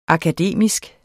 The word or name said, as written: akademisk